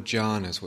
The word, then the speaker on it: jhana: So I want to talk tonight about the four jhanas, what's called the four jhanas, which are states of deep samadhi, deep samatha, deep absorption in concentration. Jhana is a word, is a Pali word, and it's spelled J-H-A-N-A. In Sanskrit, the word is dhyana, D-H-Y-A-N-A.